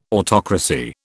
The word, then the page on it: autocracy: British English